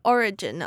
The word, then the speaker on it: origin: 要注意这边重音是放在前面的 O, 而不是后面的 origin, 所以是 origin.